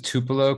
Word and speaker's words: Tupelo: And through, is you pronounce it Tupelo correctly? Is it our? – Yeah.